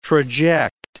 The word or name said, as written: traject